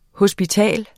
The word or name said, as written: HOSPITALET